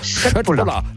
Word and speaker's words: Köttbullar: Ja, Köttbullar. – Ja! Oder wenn man es ganz korrekt aussprechen, ich habe mir sagen lassen, das K wird in Schweden wie ein Sch ausgesprochen. Also wenn es Köttbullar, hat es doch geklappt.